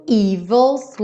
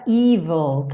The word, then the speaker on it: evil: Something evil's lurking in the dark. Cuidado com essa palavra evil, tá? Porque ela se escreve com E no começo, então cuidado para você não falar evil. Evil, ok?